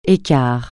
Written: écart

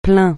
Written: plaint

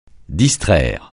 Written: distraire

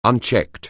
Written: unchecked

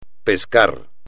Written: pescar

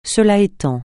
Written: cela étant

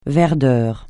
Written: verdeur

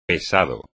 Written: pesado

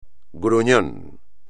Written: gruñón

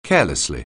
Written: carelessly

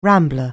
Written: rambler

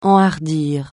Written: enhardir

